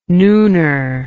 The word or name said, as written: nooner